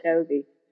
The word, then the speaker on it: Franciosi: Uh, joining us today is uh, Aisling Francesi. Am I saying that correctly? – So close. um, Aisling Franciosi.